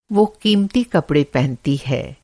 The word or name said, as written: क़ीमती